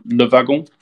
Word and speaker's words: Le Wagon: or Le Wagon, as it's pronounced in French.